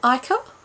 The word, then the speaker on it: ichor: Is that how you say it? Ica? – Yeah, I think so. – I-C-H-O-R? – Yeah.